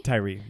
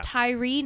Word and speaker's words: Tyre: Tyree. Yeah. – Tyree Nichols.